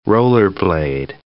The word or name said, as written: Rollerblade